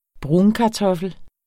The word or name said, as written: brunkartoffel